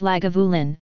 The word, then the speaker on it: Lagavulin: Lagavulin, pronounced Lagavulin,